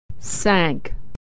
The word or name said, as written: sank